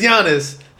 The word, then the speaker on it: Giannis: Yo, you finally pronounced his name as Giannis.